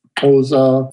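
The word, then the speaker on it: Xhosa: Oza, that sound you hear is the right way to pronounce it.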